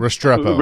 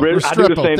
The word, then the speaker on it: Restrepo: Xavier Restrepo, how do you say his name? – Restrepo. – Restrepo, thank you.